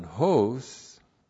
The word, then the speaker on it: hos: And then the relative pronoun hos. H long O S, not long O, excuse me. H O S. And it's a relative pronoun.